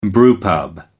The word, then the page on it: brewpub: American English